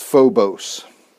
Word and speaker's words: phobos: Fear is the word phobos. It's pronounced phobos.